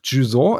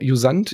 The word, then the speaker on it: Jusant: Ähm, ich will noch Juson erwähnen. Juson, Jusant, ich glaube, das wird französisch ausgesprochen, mit J geschrieben.